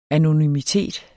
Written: anonymitet

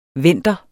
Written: vender

